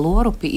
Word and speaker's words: Lorupi: Un savukārt pareizrunas vārnīcā paskatījos par, par, eh, par, par eh, lorupi ir ar o. Jā, tā norma pareizrunas, pareizrakstības pareizrunas vārnīcā Lorupi, Lorupieši, jā, te arī tā minēti.